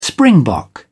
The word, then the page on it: springbok: British English